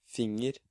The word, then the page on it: finger: Norwegian